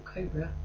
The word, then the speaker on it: Cobra: and he pronounces it Cobra. Because we've always found it a strange phenomenon. If you talk about a snake, you say Cobra. But if you're in a restaurant, you say Cobra. But that's not how it's said.